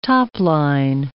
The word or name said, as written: topline